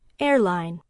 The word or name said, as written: airline